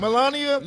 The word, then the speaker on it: Melania: Sybil, how you pronounce her name? Melania? – You got it.